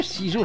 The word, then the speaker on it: Cizo: CISO, ça s'écrit C-I-Z-O.